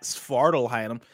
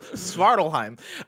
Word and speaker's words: Svartalfheim: In the title, the correct way for Google Docs, it's there's an F before the H. – So it's Svartalheim? – No, Svartalheim? I think the F is silent.